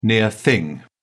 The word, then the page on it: near thing: British English